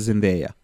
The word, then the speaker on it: Zendaya: Inače izgo, izgovara se Zendeja. Zendeja.